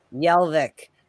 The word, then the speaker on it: Jelvik: So fifth round, 149th pick, Oscar Jelvik. Is that how you say it? – Jelvik. – Sorry. Yes. I know you told me earlier. Well, I never know what the J, if it's going to be a Y sound or it's going to be a G sound or it's going to be a J sound.